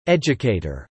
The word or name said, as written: educator